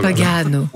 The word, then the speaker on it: Pagano: L'accent est sur le deuxième A. Oh là là. – Pagano.